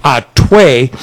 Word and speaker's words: Hatuey: They have a beer called Atuey, H-A-T-U-E-Y. It's, the H is silent, Atuey.